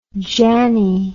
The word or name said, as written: janney